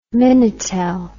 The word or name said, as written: minitel